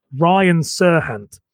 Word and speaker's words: Serhant: Wait, say his name again? – Ryan Serhant. – Serhant. Name. – Serhant. – Okay, Ryan Serhant.